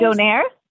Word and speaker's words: Donair: I can't pronounce it. Doliers? Doliers? – Donair? – Donair.